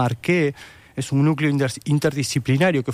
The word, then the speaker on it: ARCHE: Sí, para quienes tengan interés, les recomiendo que visiten la página ARQUE, que se escribe ARCHE,